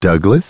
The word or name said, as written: Douglas